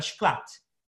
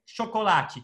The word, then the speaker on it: chocolate: Więc ponownie, szokolaci, czyli chocolate. I zauważy szklat. Masz ten twarde T.